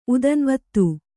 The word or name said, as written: ಉದನ್ವತ್ತು